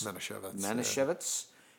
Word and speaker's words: Manischewitz: And the, how do you pronounce, is it Manischewitz? – Manischewitz. – Yeah.